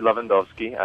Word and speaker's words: Lewandowski: Uh, well, it's Cory Lewandowski.